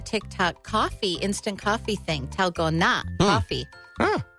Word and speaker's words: Dalgona: It's spelled D-A-L-G-O-N-A, but it's pronounced Talgona. – Talgona.